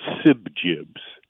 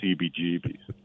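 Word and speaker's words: CBGB: I thought was called Sibjibs, but it turned out that you pronounced it by saying each of the letters, and it was CBGBs.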